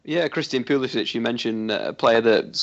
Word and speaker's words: Pulisic: They've got that young talent, Pulisic.